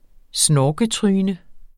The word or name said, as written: snorketryne